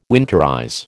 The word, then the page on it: winterize: American English